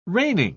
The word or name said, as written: reigning